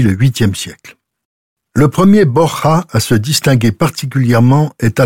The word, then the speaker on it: Borja: Le vrai nom des Borgia est Borja, qui se prononce avec un H aspiré à l'espagnol.